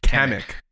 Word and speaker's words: Kamek: Kevin Michael Richards as Kamek. Did I pronounce that right? – Kamek.